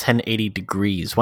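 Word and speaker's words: 1080°: 1080 snowboarding. – I think it's pronounced 1080 degrees. 1080 degrees snowboarding.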